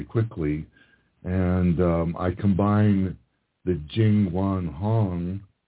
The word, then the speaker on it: Jingwan Hong: J-I-N-G-W-A-N-H-O-N-G.